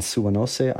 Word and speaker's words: Suwanose: It's like, so one of your major turning points as you started to move into this ag world was on Suwanose Island. Am I pronouncing that correctly? – Yeah.